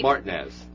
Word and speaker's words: Martinez: All right, there's a small town in Georgia outside of Augusta, and it is spelled M-A-R-T-I-N-E-Z. How would you pronounce that? – Martinez? – Yeah, right? – Martinez. – Yep, it's Martinez.